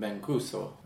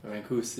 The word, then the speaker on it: Mancuso: Yeah, it's actually, well, in Italy, I think they would pronounce the surname Mancuso. Mancuso, yeah, so it's slightly different than here.